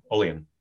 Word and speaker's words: Olean: Oddly, I could not get a hotel room in St. Bonaventure or I still never pronounce it correctly. – Olean, Olean, right? – Yeah,